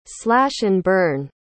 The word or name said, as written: slash-and-burn